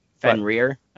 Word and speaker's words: Fenrir: Fenrir? I was pronouncing it correctly. Incorrectly. – Yeah, you got to really punch the rear on that one.